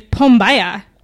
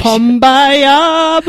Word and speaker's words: Pombaya: But this seltzer is Pombaya. Which sounds like Kumbaya. – That is the correct pronunciation. – Pombaya, my lord.